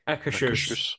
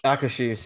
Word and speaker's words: Akershus: Akershus. – Akershus, yeah.